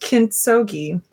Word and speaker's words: Kintsugi: I believe it is pronounced Kintsugi. Hard G.